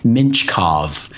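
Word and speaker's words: Mintyukov: and that's Pavel Minchkov. That's the pronunciation. I watched a Saginaw Spirit broadcast to hear how they say it.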